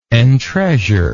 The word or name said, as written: entreasure